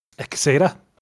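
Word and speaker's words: Quesada: Like, yeah, there again, it's Gonzalo Ecceda, that's how you pronounce it.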